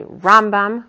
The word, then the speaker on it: Rambam: Rambam, we emphasize the first part of the name.